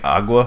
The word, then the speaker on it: agua: I had to stop saying agua because Brie yelled at me. – Agua. – She's like, you don't really pronounce the G.